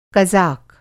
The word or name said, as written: कज़ाक